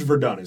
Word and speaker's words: Verdun: But chapter 8 is the Forbidden Forest, and I may pronounce this wrong because I do not speak French, but it's called Zone Rouge Verdun or Verdun, France. – It would be Rouge Verdun is correct.